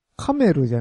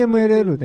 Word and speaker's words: CMLL: メキシコの、 CMLL っていう団体のビッグマッチが。 – はいはい。 CMLL でいいんですか？ – うん。 – カメルじゃないんですよね、あれ。 – 何が？あ、読み方？ – 読み方。ちゃうんですね。 – CMLL でいいんですね。